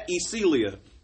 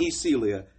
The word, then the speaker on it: Yecheilyah: It's at Ecelia. And that's spelled Y-E-C-H-E-I-L-Y-A-H. – Run it back one more time. Run it back one more time. – At Ecelia. Y-E-C-H-E-I-L-Y-A-H.